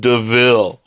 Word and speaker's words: de Vil: looks like devil, but it's pronounced DeVille.